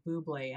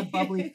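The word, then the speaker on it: bubly: And then the bubbly, I want to pronounce it Buble, and I know that's a marketing thing because Michael Buble is in the commercial, but it's the bubbly, the caffeinated fizzy drinks.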